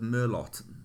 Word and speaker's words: Merlot: I ordered a bottle of Merlot.